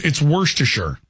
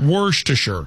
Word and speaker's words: Worcestershire: It's Worcestershire. That's how it's pronounced. – Worcestershire? – Worcestershire.